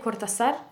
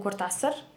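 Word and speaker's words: Cortázar: E, dakle, nije Kortasar, nego Kortasar.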